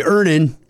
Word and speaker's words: Earnin: Earn in. E-A-R-N-I-N is pronounced earnin.